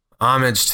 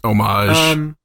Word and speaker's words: homage: So today's going to be a homage to the past. – It's pronounced homage. – Homage. Yeah, I was trying to say omelet.